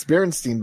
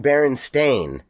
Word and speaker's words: Berenstain: When I was a kid, I remembered thinking, God, I remembered seeing Berenstain and thinking it was weird that everyone said it wrong. – That's how I felt about it. – I felt it like when Hannah was born. It stood out to me like, it's Stain. I remembered it the way everyone misremembered.